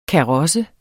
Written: KAROSSERNE

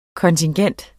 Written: kontingent

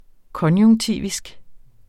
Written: konjunktivisk

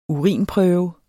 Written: urinprøve

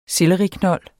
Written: SELLERIKNOLD

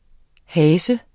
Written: hase

